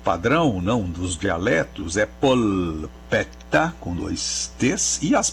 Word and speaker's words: polpetta: E no caso da palavra que ele pergunta, polpetta, é assim que se diz em italiano, polpetta, que se escreve com L no meio e dois T's, polpetta, que é a nossa almôndega, é o nosso bolinho, né? – Uhum.